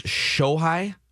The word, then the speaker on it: Shohei: But I believe the first name pronunciation is Shohei. S-H-O-H-E-I.